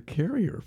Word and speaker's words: Carrier: It's Carrier because I know the family. – Oh, okay. – But it's spelled right. You spelled it right. – Oh, okay. Yeah. Oh, it's pronounced Harrier? – Carrier, yeah.